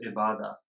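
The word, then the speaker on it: Ibada (عبادة): It's pronounced as Ibada. So it's an Arabic word.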